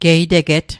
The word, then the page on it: geiteget: Mi'gmaq